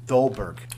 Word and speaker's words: Dolberg: You had, I'm going to butcher his name, this poor guy, Dolberg.